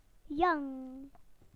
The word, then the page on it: young: British English